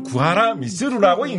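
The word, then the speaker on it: 구하라 미츠루 (久原躬弦): 구원 궁현을 구원이 구하란데 궁현을 음으로 읽으면 규겐이 되는데 네. 이거를 뜻으로 읽으면 미츠루가 됩니다. 그래서 이 사람을 구하라 미츠루라고 읽는 게 맞습니다.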